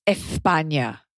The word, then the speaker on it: España: First of all, it's pronounced España, okay? So lisp like a local.